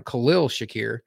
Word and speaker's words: Khalil Shakir: The wide receivers they have under contract are Stephon Diggs, Gabe Davis, who's going into the last year of his rookie deal, Khalil Shakir, we've been pronouncing his name wrong, Khalil Shakir, and then Isaiah McKenzie, and I have his number here.